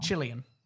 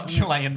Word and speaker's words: Cillian: Cillian. – Chilean. – It's not Chilean Murphy.